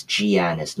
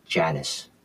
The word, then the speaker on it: Giannis: And by the way, it's Giannis, not Janice.